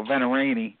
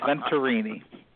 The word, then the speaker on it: Venturini: And when we were talking, I asked you how do you pronounce your last name, and you told me that your granddad had always told you to be proud to pronounce that T in Venturini. – Yeah, because I had a tendency when I get talking to people, I just go Venturini, and he used to get so pissed at me for not pronouncing the T. So, yep, it's Venturini.